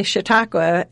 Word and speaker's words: Chautauqua: So, we actually say Chautauqua, and it's a U in every syllable, so that's a way to remember it.